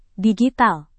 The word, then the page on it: digital: indonesian